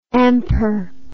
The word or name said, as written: amper